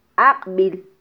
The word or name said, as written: aqbil